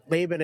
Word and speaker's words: Laban: I'm multilingual, but so Laban in English. Laban en español.